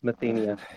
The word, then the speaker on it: Mathenia: It's Mathenia, but that's whatever. – I was close. I put the emphasis on the wrong syllable. I was saying Mathenia.